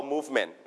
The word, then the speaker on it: Movement: That's all movement. นะครับ movement. What is movement, everybody? When you move your body, what is it? การอะไรการเคลื่อนไหวนั่นเอง movement. Movement. All right, and you stress on the first syllable, move.